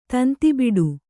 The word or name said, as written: ತಂತಿಬಿಡು